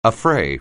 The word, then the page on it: affray: anglais